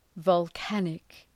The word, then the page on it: volcanic: vɒl’kænık